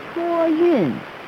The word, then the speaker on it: 托运: 托运.